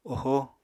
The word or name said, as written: ohó